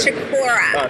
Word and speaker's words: Chiccorra: It's Chiccorra.